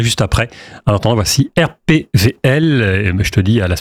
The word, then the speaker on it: RPWL: RPWL, RPVL. – W. – Ça s'écrit W, mais on dit RPVL. – Ah oui, parce qu'en allemand, le W ne se prononce pas, voilà.